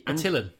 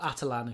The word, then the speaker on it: Attilan: Attilan. – Attilan. – Or Atalan, if you really want.